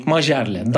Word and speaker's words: Majerle: Hem de Jerry Krause'un e, Phoenix'te oynayan Dan Ma- Majerle mi? Mayerle mi? Nasıl okunuyor bilmiyorum. Dan, yani Türkçesini. – Majerle. Dan Majerle.